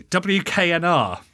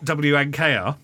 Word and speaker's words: WKNR: This is one of those stations that you have to pronounce carefully. WKNR, not WNKR.